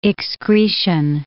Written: excretion

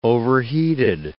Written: overheated

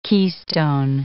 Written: keystone

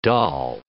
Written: dal

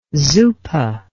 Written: zuppa

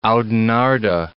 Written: Oudenaarde

